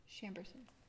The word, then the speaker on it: Chambourcin: How is Chamberson even pronounced? – Chamberson. – Yeah, and that's how I would say it. – In the Deep South, it's Chamberson. – Less, so, shh, as opposed to shh.